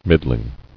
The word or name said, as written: Middlings